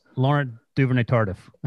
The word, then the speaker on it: Laurent Duvernay-Tardif: First off, apologies to Laurent Duvernay-Tardif because Breach mispronounced his name, which is no surprise given what I say. That's pretty close. – Laurent Duvernay-Tardif. That's what you said. – Oh, I didn't go full French.